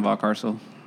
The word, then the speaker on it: Valcarcel: Jason Valcarcel.